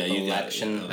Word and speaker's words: Appalachian: Did I say that right? – Yeah, Appalachian. – Appalachian? – I mean, it's awkward, but it's fine. – That's all awkward. – You said it right.